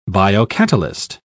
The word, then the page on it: biocatalyst: British English